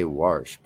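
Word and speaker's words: wash: That is the proper way to say Warsh, by the way. I'm tired of this shaming. You know, we bastardize the English language anyway and then call it our own.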